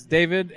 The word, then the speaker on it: David: So we're day three of the Utah Halloween Expo, and Jeremy and I are here with Dave Ace's Faces, right? Did I say that correctly? – It's David Ace's Faces, yep.